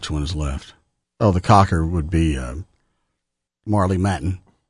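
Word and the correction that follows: Marlee Matlin: Did I get the name right? – Yeah, Marley Matlin.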